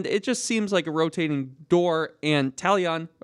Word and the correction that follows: Tyon: or how do we say his Tyon. – Name? Tyon.